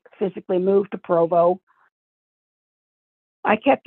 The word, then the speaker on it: Provo: And I remember that I saw your journal or diary where you had drawn out the, before you got to Provo, what the house was going to look like that you're going to live in.